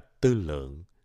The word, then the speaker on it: tư lượng: tư lương đọc là tư lượng